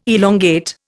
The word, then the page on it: elongate: Indian